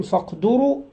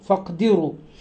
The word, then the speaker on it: فاقدروا: قال فاقدروا أو فاقدروا بهمزة وصل، فاقدروا الألف التي بين الفاء والقاف همزة وصل لا تنطق، وضم الدال وكسرها، يعني ممكن نقول فاقدروا أو فاقدروا.